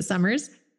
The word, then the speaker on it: Sommers: In fact, my new YouTube handle is @mandysummers, and my last name is actually spelled with an O, so it's Sommers, but we pronounce it Summers.